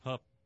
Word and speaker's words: Hough: Hough rhymes with tough.